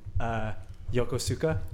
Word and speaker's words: Yokosuka: I lived in Japan, and it was called, it's Y-O-K-O-S-U-K-A, and there's many people that said Yokosuka. It's pronounced Yokosuka.